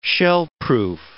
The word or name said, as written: shellproof